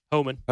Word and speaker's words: Homan: Is that how you pronounce it? Homan or Homan? – Yeah. Homan.